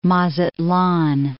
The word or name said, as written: Mazatlan